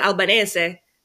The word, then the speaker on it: Albanese: And they'll be like, oh, how do you say it? Albanese or Albanese? And I'll just completely blank because my family says it Albanese because they're all Italian.